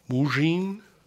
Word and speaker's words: Mougin: It's called Secondary Superheroes of the Golden Age by Lou, and I'm going to butcher this, Mugin, M-O-U-G-I-N, Mugin.